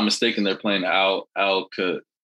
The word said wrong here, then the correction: Aliquippa: You're from PA. You're not supposed to be mispronouncing Pennsylvania. I'm the guy from South Carolina who mispronounces every town in PA. You know, I'm from down south. I don't know these towns. You're supposed to know Aliquippa, man.